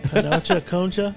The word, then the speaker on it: panocha: Yeah, it rhymes with La Concha. – Panocha, Concha.